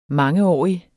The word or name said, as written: mange